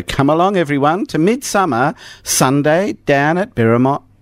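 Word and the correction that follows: Birrarung Marr: Birrarung Ma.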